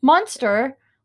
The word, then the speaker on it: Munster: Munster, like the cheese.